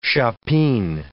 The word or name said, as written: chopine